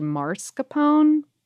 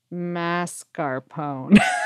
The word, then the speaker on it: mascarpone: The first half of the word throws a lot of people, but it should rhyme with NASCAR. It's spelled like mascara, but something about it makes it very easy for our brains to scramble the phonemes in the middle. I did definitely, side note, previously say mascarpone instead of mascarpone.